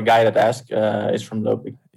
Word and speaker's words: Lopik: So how's it actually pronounced? – Lopik and Benchop.